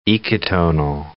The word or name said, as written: ecotonal